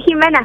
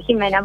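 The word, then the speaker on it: Ximena: Jimena está bien, Jimena, porque es con X, pero todo el mundo me dice Jimena, simplemente.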